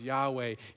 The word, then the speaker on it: Yahweh: It goes back 3,500 years, and it was back when they used to pronounce the name of God as Yahweh.